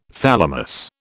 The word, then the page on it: thalamus: British English